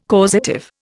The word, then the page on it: causative: Indian